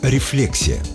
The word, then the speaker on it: рефлексия: Те, кто увлекается психологией, знают слово «рефлексия». Да-да, только так, с ударением на второй слог. Именно такой вариант разрешает орфоэпический словарь русского языка. Вариант с ударением на «и» неправильный.